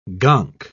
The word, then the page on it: gonk: British English